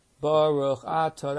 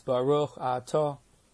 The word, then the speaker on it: Baruch Atah: Baruch Atah, that's wrong. It's Baruch Atah. The accent's on the last syllable, in most cases, not the second to last.